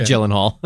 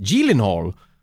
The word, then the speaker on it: Gyllenhaal: I think Jake he did a bit on Conan where he was like, you know, actually we're of Swedish descent or something, and my last name is actually pronounced Gyllenhaal.